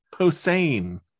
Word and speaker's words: Posehn: Posehn.